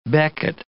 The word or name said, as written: becket